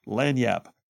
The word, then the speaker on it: Lagniappe: If you're not familiar with Lanyap, it's spelled, by the way, it's spelled L-A-G-N-I-A-P-P-E, Lanyap. Lanyap from Mobile is a pretty good news source, and they will give you like deep dive stuff.